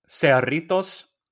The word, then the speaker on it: Cerritos: It's the Cerritos. – Oh, Cerritos. Yeah, that's right. – If you pronounce that in Spanish, it's Cerritos, and it translates to the little zeros.